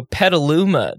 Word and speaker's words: Petaluma: And I was like, No, Petaluma. That's how it's pronounced. But then I looked at the word and I was like, you could very much pronounce that Petaluma and it would absolutely make sense.